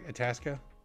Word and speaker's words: Itasca: Itasca? – You did.